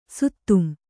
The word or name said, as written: ಸುತ್ತಂ